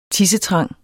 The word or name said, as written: tissetrang